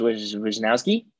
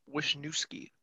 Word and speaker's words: Wishnowsky: Mitch Wishnowsky. Did I say it correctly? Yeah, I believe so. – Not like he, is our placeholder. Collinsworth called him Wisniewski last night.